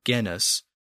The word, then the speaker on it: genus: It originally was pronounced with more of a hard G at the beginning. So it was genus.